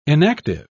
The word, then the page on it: enactive: British English